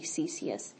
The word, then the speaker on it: caesious: but the probably the most common American way would be CCS. like Caesar. It's spelled C-A-E-S-I-O-U-S.